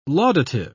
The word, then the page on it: laudative: British English